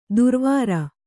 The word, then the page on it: ದುರ್ವಾರ: durvāra